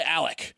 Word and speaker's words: Alex: whose name is spelled Alex. A-L-E-X. Apparently, he goes by Alec or Alec. So wait, what? His name is pronounced Alec Murdaugh, and it's spelled Alex Murdaugh.